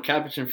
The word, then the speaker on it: Capuchin: So I'm a Capuchin Franciscan friar.